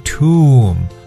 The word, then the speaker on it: tomb: 在很早之前的节目当中，我跟大家有去说过，英文单词凡是以 n b 结尾的话呢，b 都不发音。 OK，我们说过，比如像爬山的爬 climb，对不对？这个啊，麻木的 numb，还有坟墓 tomb，还有梳子 comb 等等，凡是以 m b 结尾的词呢，b 都是哑音状态。